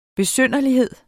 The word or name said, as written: besynderlighed